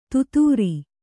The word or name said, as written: ದಾದರಾ